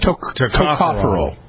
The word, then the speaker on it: tocopherol: Tocopherol.